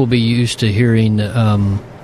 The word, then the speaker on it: Yahweh: You hear it, Pastor Scott and I say it that way, and that mainly comes from the idea that we, without vowel points, the letters of Yud Heh Vav Heh, if you just pronounce the letters themselves, it's Yah-Vah-Ha. So it's Yahweh, and that kind of came into English as Yahweh, Yahweh.